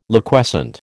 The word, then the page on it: Liquescent: American English